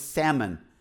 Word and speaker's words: Salmon: Who had a son named Nashon, who had a son named Salmon, or as my wife says, you don't pronounce the L, it's Salmon. So why did they put the L in there?